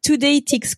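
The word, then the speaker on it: TodayTix: Today ticks comme les tickets du jour, quoi. Ouais, Today ticks et sauf que ticks ça s'écrit ah, T-I-X.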